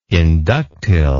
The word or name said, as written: inductile